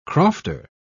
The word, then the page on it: crofter: British English